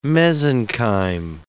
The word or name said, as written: mesenchyme